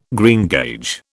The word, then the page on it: greengage: British English